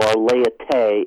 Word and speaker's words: laïcité: Yes, the third model is the laicism model, or laïcité, if you're going to use the French pronunciation, which I'm probably mangling badly, but this has a different attitude towards religion.